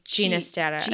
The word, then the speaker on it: Ginastera: So I'm also pronouncing his name Hinastera because when I went to Forvo and every time I've heard it, that's what people pronounce it as. But in quite a few notes, I found that he preferred it pronounced with the soft G as in George. So it could be Ginastera as well.